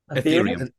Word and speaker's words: Ethereum: Is it, how do you pronounce it? – Ethereum.